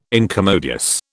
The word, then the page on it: incommodious: British English